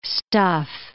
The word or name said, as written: stuff